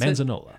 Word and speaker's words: Manzanola: And is it Manzanola? Is that how you pronounce Manzanola.